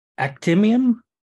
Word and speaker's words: Actemium: So this is Actemium? – Yes. – That's how you say it.